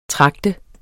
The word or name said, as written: tragte